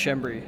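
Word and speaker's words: Schembri: It's pronounced Shembri. Yeah, it's a bit of a weird one. – Shembri. Is that Maltese? – Yeah. – Right. – Yeah, Maltese name.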